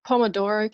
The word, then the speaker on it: Pomodoro: Pomodoro cube.